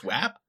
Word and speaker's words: WAP: It is pronounced WAP? – Yeah.